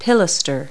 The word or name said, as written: pilaster